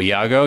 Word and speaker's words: Iago: Was it Iago? Is that how it pronounced? – Iago, yeah.